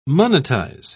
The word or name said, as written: monetize